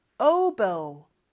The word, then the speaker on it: oboe: 영어로는 oboe, oboe, oboe라고 하지 않아요. oboe.